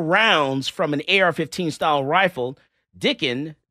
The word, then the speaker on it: Dicken: Elisjsha Dicken. – Say his name. – Dicken. – Dicken. – Yeah. – Oh, I'm saying with the S at the end? – No S.